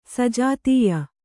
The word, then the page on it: ಸಜಾತೀಯ: sajātīya